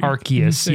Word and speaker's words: Arceus: Arceus. Yeah, if you're ever around children, make sure you say that right.